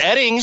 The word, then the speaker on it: Oetting: Mark Oetting. – Hold on. It's pronounced Edding. – But it's spelled O-E-T-T-I-N-G. – Yes, and O-E-T-T-I-N-G.